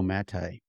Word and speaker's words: Maté: and it's The Myth of Normal by Gabor Maté. – The Myth of Normal? By Gabor? – Maté. – M-A-T-E. G-A-B-O-R. – And then G-A-B-O-R. Maté as in mate. – Maté with an accent on the end. M-A-T-E with a cute accent on the end.